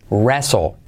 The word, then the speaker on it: wrestle: La pronunciación de wrestle, ya ves que la T no se pronuncia, pero eh, para acordarte es como si una persona hablando inglés español raro dijera, yo wrestle cada día, wrestle.